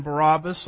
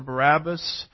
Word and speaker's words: Barabbas: We've studied a little bit about this man Barabbas or Barabbas. Depends on which syllable gets the emphasis, as I like to say.